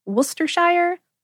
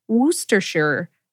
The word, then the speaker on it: Worcestershire: And according to the company that originally created it, Lee and Perrins, it can be pronounced Worcestershire, Worcestershire, or Worcestershire. Worcestershire sauce is used to make good things even better because it packs a lot of savoriness, sometimes called umami.